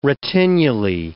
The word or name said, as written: retinulae